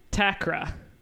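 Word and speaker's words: Atakora: So I read Conjure Women by Effie Takra. There is a silent A at the beginning of her name, of her last name.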